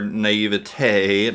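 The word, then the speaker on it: naïveté: Yeah, well, and and I think this speaks to our naivete, not as um, is that how you pronounce that? Um, Perfect. okay, good. Very um, French.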